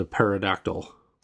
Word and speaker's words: pterodactyl: Because it looks painfully awesome as a pterodactyl. – It looks like when it's in its various... – I'm pronouncing the P to upset any paleontologist that might be listening.